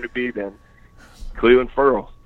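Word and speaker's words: Ferrell: As an ex-Clemson beat, thoughts on Cleveland claiming his last name is pronounced Furl? – I mean, I feel bad that it seems you see Farrell so often that, Right, because you've got to Farrell this whole time.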